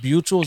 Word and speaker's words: Buechel: I see that you are in a department called, is that Buechel? Is that how you pronounce it? – Correct.